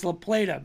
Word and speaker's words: La Plata: I think I called it La Plata. He goes, no, it's La Plata.